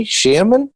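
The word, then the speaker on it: Shearman: Am I pronouncing that right, Barney? Sherman? – Yeah, that's right.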